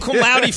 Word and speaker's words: cum laude: He graduated, how do you say it, cum laude? – There's let's see.